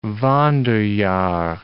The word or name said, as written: Wanderjahr